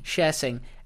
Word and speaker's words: Sher Singh: Was a bloke called Sher Singh. – Okay. – And he... – Sher as in, if I could turn back. – But spelt with an S. – Okay. – Sher Singh.